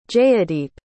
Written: jayadeep

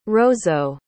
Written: roseau

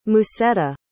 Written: musetta

